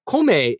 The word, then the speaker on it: kome: Kome is the, K-O-M-E is the most common way to say rice in Japanese.